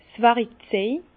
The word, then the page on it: 's Wärikzej: Bas Rhin; Herrlisheim